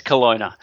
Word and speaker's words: Kelowna: Yeah, it's Kelowna.